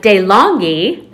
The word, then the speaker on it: DeLonghi: My DeLonghi, that's how you pronounce it. I looked it up.